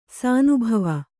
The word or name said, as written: ಶಾನಬಾವ